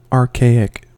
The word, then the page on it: archaic: American English